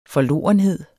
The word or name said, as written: forlorenhed